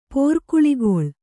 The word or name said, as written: ಪೋರ್ಕುಳಿಗೊಳ್